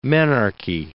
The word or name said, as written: menarche